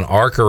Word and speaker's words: ARCA: You said there was an ARCA race scheduled.